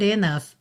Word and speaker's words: enough: Suffered enough. Enough é N-O-U-G-H. Você pensa em enough. Of é esse som final, tá? Of. Então enough.